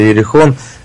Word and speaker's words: Иерихон: Называется она «Гибель отряда Иерихон». Во-первых, скажи мне, правильно ли я поставил ударение в последнем слове, а во-вторых, объясни мне, что оно значит. – Правильно, песня называется «Гибель отряда Иерихон».